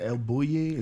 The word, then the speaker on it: El Bulli: Was it El Bulli? Is that right. How you say it? – Yeah, but just about anywhere in Spain is going to be a special place with great food, and it's going to be beautiful.